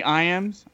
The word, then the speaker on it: Iams: If you guys find me on Xbox, they call me Iams. Iams is spelled just like the dog food.